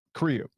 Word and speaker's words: Cribb: I got Sherry Cribbs. Did I say your name right? – Cribb. No S.